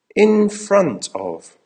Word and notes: in front of: American English

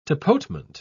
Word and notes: tapotement: British English

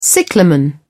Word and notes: cyclamen: British English

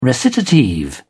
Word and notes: recitative: British English

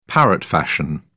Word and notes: parrot fashion: British English

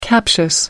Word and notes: captious: American English

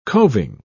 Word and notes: covin: British English

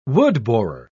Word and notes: woodborer: British English

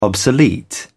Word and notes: obsolete: British English

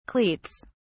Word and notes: cleats: British English

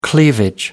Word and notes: cleavage: American English

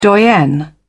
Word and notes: doyen: British English

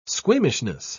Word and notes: squeamishness: British English

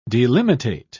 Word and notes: delimitate: British English

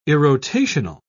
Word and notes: irrotational: British English

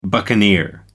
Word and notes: buccaneer: American English

